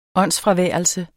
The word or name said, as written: åndsfraværelse